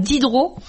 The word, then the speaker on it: didro: Et si on veut parler des adverbes, il y a l'adverbe didro. – Oui, alors didro, oui, ça rappelle le nom du célèbre homme des lumières. Là. Mais alors, ça s'écrit D-I-D-R-O, didro.